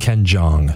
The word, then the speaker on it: Jeong: Ken Jeong.